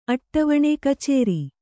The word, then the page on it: ಅಟ್ಟವಣೆ: Kannada